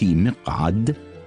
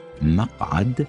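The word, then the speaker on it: مَقعد: فنطقنا لكلمة مقعد بكسر الميم نطق خاطئ، والصواب أن نقول مقعد بفتح الميم.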